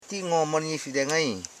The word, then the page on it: ngomonyifide: ngo.mo.nyi.fi.de; Koro